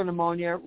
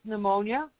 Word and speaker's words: pneumonia: Pneumocystis pneumonia or pneumocystis. Yeah, I love how people, I don't know why they put a P with pneumonia when you don't even pronounce it. The P is silent.